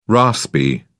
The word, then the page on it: raspy: British English